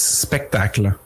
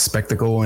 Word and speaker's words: Spectacle: It is stylized to be pronounced à la French, so it's Spectacle. That would be very specifically. But I don't mind. I understand that it's, you know, it reads as Spectacle and it's fine because it's the same word as in like a show, a display, a spectacle.